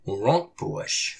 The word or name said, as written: Wurôkpuwush!